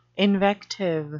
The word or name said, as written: invective